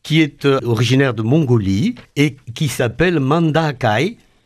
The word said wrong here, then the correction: Mandaraï: Je crois qu'on prononce Mandaraï. – Ou Mandaraï, d'accord. Oui, bien sûr, donc c'est du mongol.